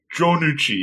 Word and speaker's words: Junuchi: But tell them is it Junuchi? Because that sounds like somebody who would be like a cousin of Fonzarelli Happy Days. This name, is that how you pronounce it? – Junuchi, yeah. Junuchi and Honda.